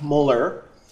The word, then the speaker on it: molar: So if I have a 0.50 molar, that's how that's pronounced, capital M used as a unit is pronounced molar, the molarity is the concentration itself.